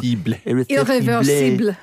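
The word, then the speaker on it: Irréversible: By the way, 4 stars for your pronunciation of Irreversible. That's the first time I've ever heard anyone actually go Irreversible.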